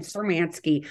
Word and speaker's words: Cermanski: Cermanski.